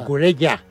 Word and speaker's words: Cucurella: Por cierto, ¿cómo se dice? ¿Cucurella o Cucurella? – Bueno, Cucurella. – Ya, yo lo he dicho en mi vida. Con doble L.